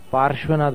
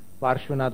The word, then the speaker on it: पार्श्वनाथ: पार्श, स कैसा बोला आपने, पार्श्व, पार्श्वनाथ भगवान। हम थोड़ा सा उच्चारण पर भी ज्यादा कान पकड़ते हैं लोगों के, इसलिए बुरा मत मानना, पार्श्वनाथ भगवान।